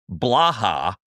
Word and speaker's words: Blaha: If I'm Caleb Blaha, I believe that is his how you say his last name, B-L-A-H-A, I say, yeah, give me another year of eligibility.